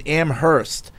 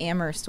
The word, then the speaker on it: Amherst: Amherst. Us Western Massers know the H is silent. – The worst was the people I went to UMass Amherst with that didn't know how to pronounce Amherst.